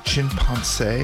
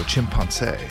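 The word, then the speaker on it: Chimpancé: Before that, it was Chimpanse. It's written like chimpanzee, except it's with a CE at the end with the accent on the E. So Chimpanse.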